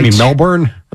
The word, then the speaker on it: Melbourne: Melbourne? – That's pronounced Melbourne. For Melbourne? – The locals. Melbourne. Yeah, there were locals.